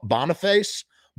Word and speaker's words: Boniface: Boniface.